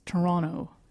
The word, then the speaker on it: Toronto: Yes, as Ben Affleck reminds us in Argo, the true Canadians do not pronounce the T. It's Toronto.